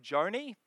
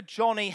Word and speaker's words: Joni: I always pronounced her name wrong. I said Joni, but I did some research. It's actually Joni after her father, John.